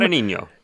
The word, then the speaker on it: Guadagnino: Guadagnino.